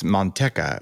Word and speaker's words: Manteca: I used to live in a town called Manteca. And, well, in Spanish, it's Manteca, which means lard,